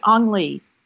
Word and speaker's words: Ang Lee: Ang Lee.